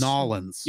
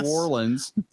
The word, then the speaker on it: New Orleans: Be like, it's actually, you know, I know it looks like it's spelled N-E-W space O-R-L-E-A-N-S. – New Orleans. – But in reality, it's spelled apostrophe N-A-W-L-I-N-S. Nolens.